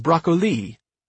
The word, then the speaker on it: broccoli: The vegetable's name, he learned, was broccoli, pronounced with emphasis on its very last syllable.